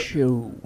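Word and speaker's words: Shough: They do have a very highly rated guy from high school on the roster now, Tyler Shaw, I think is his last name, or Shaw. It's S-H-O-U-G-H. You pronounce it. – S-H-O. – Like though, but show. But with an S. Yeah, show.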